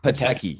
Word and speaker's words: Pataky: It's Elsa Pataky.